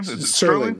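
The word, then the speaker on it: Serling: Sterling. Like yes, sir. Yes, sir. Okay. Sterling.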